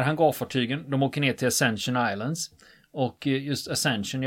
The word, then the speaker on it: Ascension: Och eh, när jag försökte rota i det här eftersom det är brittiska mm. öar så kör vi Ascension. med det uttalet.